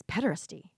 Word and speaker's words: pederasty: No, it's pederasty.